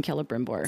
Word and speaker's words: Celebrimbor: Thank you for putting the correct emphasis on the correct syllables in Celebrimbor.